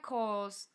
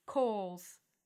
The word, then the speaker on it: Cowles: Brent Coles, that's how you say his name. You spell it C-O-W-L-E-S, Coles.